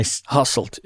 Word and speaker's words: Hasselt: So how do you say the way it looks? It looks like Hasselt, but am I... – Hasselt, yeah. Okay.